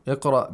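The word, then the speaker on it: Iqra (اقرأ): Iqra bismi rabbika alladhi khalaqa. – And the hamza of iqra should be heard, I think. – Yes, it's hamzatul qatta.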